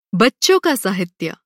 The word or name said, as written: बच्चा